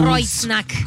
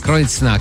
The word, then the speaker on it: Kreuznach: Kreuznach. – Come si chiama? – Kreuznach. – Kreuznach. – Perché EU si legge OI.